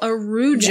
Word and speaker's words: Araujo: And before anyone yells at me, that is how she pronounced her own last name. – Okay. – On the witness stand. So. – Well, how were other people pronouncing it? – So Google says to pronounce it Araujo, and that's how I would have said it if I hadn't heard her testimony.